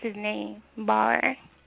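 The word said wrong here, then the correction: Baauer: I think, is he Diamond? – Bauer? – Okay, thank you. Because you corrected me last time.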